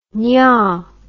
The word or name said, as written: nyahh